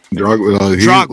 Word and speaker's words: Drogba: How do you pronounce his last name? – Dogba. – He ends up meeting Didier Dogba. – Drogba. Look, that's a legend.